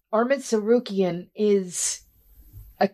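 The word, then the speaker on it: Tsarukyan: You think I'm worried about freaking Armin to Sarukian with a silent T at the beginning of his name?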